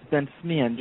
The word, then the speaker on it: Bensmihen: It's Ben-Smihen. Just ignore the H, and okay.